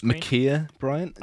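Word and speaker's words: Ma'Khia Bryant: I think it's Makia Bryant.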